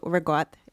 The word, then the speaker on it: ricotta: Sicilians drop the last vowel on things. – Oh. – How do you say it? – What's the word? – Ricotta. – Ricotta. – Yeah. – New Jersey. – How do you say it? – Also, so same thing. I'm from Bensner, so regot is what we say.